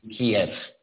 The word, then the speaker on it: Kyiv: It is most definitely Kyiv.